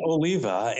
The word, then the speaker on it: Oliva: Yes, it's pronounced Oliva.